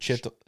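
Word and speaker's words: Chytil: They offered a first-round pick, Nils Lundqvist, and Philip, I'm sorry, I don't know how to say the last name. Is it Chittle? – Yeah.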